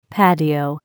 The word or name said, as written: patio